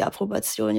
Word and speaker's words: Approbation: Die Bescheinigung, praktizieren zu dürfen, ist die Approbation, ja.